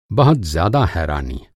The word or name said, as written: हैरानी